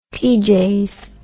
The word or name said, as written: peejays